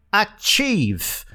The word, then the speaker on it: achieve: Que é a palavra achieve, que é alcançar. Essa é barbada. Para pronunciar achieve direitinho, apenas espirre. Isso mesmo. Diga achim, achim. Troque o M final pelo V. Achieve.